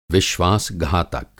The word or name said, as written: विश्वास-घातक